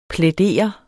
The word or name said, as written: plædere